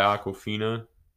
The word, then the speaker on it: Awkwafina: Shang-Chi's friend, Katie, played by Awkwafina. She was funny. Did I say her name wrong? – No, that is right, but it's such a weird name.